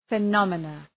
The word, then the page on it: phenomena: fı’nɒmənə